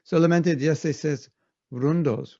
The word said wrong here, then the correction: rondas: Rondos? – Rondas. – Rondas.